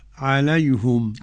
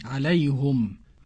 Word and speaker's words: عليهم: الحضرمي تنطق كيف فضيلة الشيخ؟ – عليهم. – عليهم.